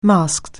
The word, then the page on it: masked: anglais